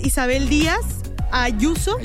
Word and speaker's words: Isabel Díaz Ayuso: Pues mira, la presidenta es Isabel Díaz Ayuso. No sé si estoy pronunciándolo bien. – Sí, correctísimo.